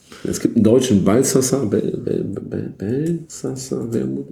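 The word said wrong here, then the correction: Balthasar Wermut: Ich weiß es nicht. Ich muss nachschlagen. Ich kriege es immer falsch ausgesprochen. Es tut mir auch leid. Balsasar-Wermut, Entschuldigung.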